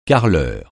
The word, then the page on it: carreleur: francais